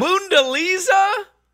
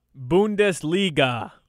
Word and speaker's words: Bundesliga: Boondaliza? Boondaliza. How do you say it? – Bundesliga.